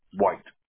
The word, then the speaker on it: White: White. – White, all right. W-H-I-T-E? – Yes. – Okay, perfect. And Eric, that's spelled E-R-I-C, right? – Yes.